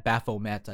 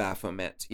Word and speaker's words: Baphomet: I saw a couple of different versions, but the Church of Satan officially had Baphomet, I believe. – Baphomet, yes.